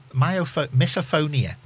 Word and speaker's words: misophonia: So we're talking about misophonia. Have I pronounced that right? – Yes.